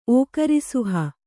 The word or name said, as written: ಓಕರಿಸುಹ